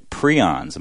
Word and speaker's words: prions: He discovered prions.